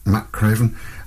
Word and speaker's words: McCraven: We have Micaiah McCraven. That's how to pronounce it, Matt Craven.